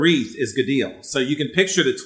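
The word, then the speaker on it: gadil (גדיל): The Hebrew word is spelled gadil, or G-A-D-I-L, and it's pronounced gadil. So G-A-D-I-L, pronounced gadil.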